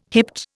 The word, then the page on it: hipped: Indian